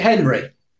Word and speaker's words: Henri: Well, it's spelled Henri, but I think he pronounces it Henry.